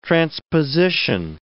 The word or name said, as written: transposition